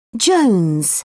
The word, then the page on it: jones: British English